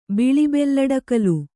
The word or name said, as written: ಬಿಳಿಬೆಲ್ಲಡಕಲು